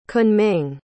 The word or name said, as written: Kunming